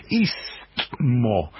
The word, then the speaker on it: istmo: Bueno, no se te ocurra poner primero la T, porque primero la S. Porque sé por qué dicen palabras tan complicadas que en general no sabemos pronunciar. Es istmo. Primero la S, después la T.